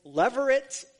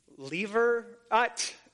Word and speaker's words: levirate: But what they're referring to in this passage is a custom which is called leveret. I think that's the way you pronounce it. L-E-V-I-R-A-T-E, leveret, marriage.